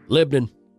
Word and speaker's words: Lebanon: People that come to town say Lebanon, like the country, because mm-hmm. – that's how it's spelled. – Pronounced Lebanon. – Yeah. – Lebanon. If you live here, it's Lebanon.